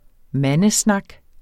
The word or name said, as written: mandesnak